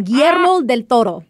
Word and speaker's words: Guillermo del Toro: Guillermo del Toro.